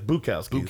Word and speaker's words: Bukowski: Bukowski. I keep putting a T in there with Butowski. – It's Bukowski.